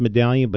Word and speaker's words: Madalian: Pronounced Medallion, but spelled M-A-D-A-L-I-A-N.